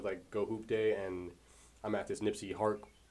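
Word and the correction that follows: Nipsey Hussle: Wait, I said that right? – You said Nipsey Hart. – Yeah, that's what I thought I said. This Nipsey Hustle Park, and he said that it was so many people, there's so many photographers, people balling up crazy and shit like that.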